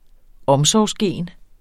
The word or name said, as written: omsorgsgen